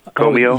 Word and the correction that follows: Comeau: Oh, yeah, the, that's okay. It's Como at, at, Fort Hays.